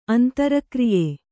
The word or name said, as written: ಅಂತರಕ್ರಿಯೆ